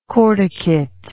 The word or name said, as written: corticate